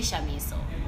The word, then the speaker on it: Shamiso: Like, this is going sideways, but if you can say Charlotte, if you can say Shannon, you can say Shamiso.